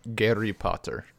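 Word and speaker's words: Gary Potter (Гарри): In Russian, it is pronounced Gary Potter. – Gary Potter? – Gary. Like, okay. Not exactly, but close enough. So, uh-huh.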